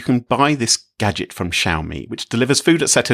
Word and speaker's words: Xiaomi: Xiaomi? – Yes, yes, Xiaomi.